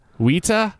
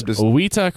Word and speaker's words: Weta: It's pronounced Weta. – Weta? – Weta. – Okay, my bad. Specifically, it's Weta crickets. – No, you're correct. I just the New Zealand accent.